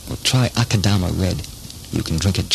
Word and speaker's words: Akadama: Akadama, it's spelled Akadama, A-K-A-D-A-M-A, and it rhymes with mama. Akadama wines are imported by Suntory International in Los Angeles. Suntory. S-U-N.